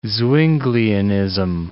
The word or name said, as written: Zwinglianism